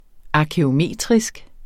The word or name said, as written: arkæometrisk